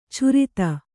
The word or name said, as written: ಛುರಿತ